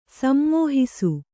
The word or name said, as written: ಸಮ್ಮೋಹಿಸು